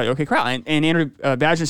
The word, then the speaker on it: Vajna: It's V-A-J-N-A, Vajna.